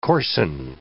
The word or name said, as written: coarsen